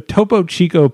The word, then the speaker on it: Topo Chico: Painted a Topo Chico painting of it's sparkling water.